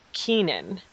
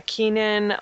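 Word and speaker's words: Keiynan: So it's an actor from Insurgent, and I think his name is pronounced Keenan. Keenan Lonsdale, and he is going to be playing Wally West on The Flash this season.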